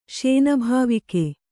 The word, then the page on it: ಶೇನಭಾವಿಕೆ: Kannada